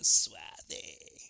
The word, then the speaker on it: suave: Antes te acostarás, en tu sueño será suave. Y me gusta esa palabra, suave. Lo dije bien, brother. Suave. Así se dice, como eso. Suave.